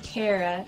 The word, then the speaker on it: Kara: Actually, my name is pronounced Kara. Like, I care a lot.